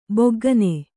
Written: ಭೊಗ್ಗನೆ

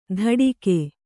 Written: ದಡಿಕೆ

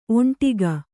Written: ಒಂಟಿಗ

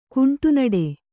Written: ಕುಂಟುನಡೆ

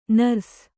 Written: ನರ್ಸ್

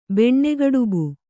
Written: ಬೆಣ್ಣೆಗಡುಬು

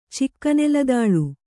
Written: ಚಿಕ್ಕನೆಲದಾಳು

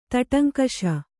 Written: ತಟಂಕಷ

